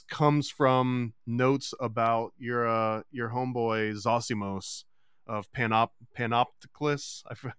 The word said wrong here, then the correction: Panopolis: Panopolis, yeah.